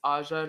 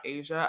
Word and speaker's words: Aja: It's quite like also, I'm pretty sure that it's Aja, not Asia. I have a cousin whose name's Asia, so that's why I pronounced her name that way, but it's Aja Barber.